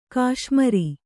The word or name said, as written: ಘಸ್ಮರ